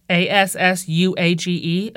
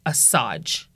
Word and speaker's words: assuage: I realized I had trouble pronouncing the word assuage. A S S U A G E, assuage. I thought that that was said assage.